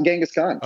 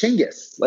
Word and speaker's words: Chinggis: Yeah, his name's not Genghis Khan. – What is it then? – It's Chinggis, like completely different.